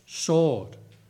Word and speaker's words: sword: Coming out of his mouth was a sharp, double-edged sword. The two-edged sword. Somebody said it was a sword. Hello. You don't pronounce the W like George W. Bush. It's a sword, not a sword.